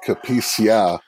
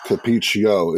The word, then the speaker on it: Cupiccia: So, if you look at the spelling, it sounds like Cupiccia, was actually pronounced capiccio. It's Italian. The A is pronounced with an O, and I wish I could tell you why, but I can't.